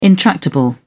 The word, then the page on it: intractable: British English